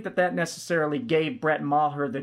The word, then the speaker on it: Maher: which that's the correct way to say his name, it's Maher.